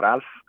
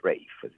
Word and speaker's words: Ralph: non si gira perché il suo nome è Rafe, si dice, se no, non si pronuncia in quel modo, non si pronuncia Ralph.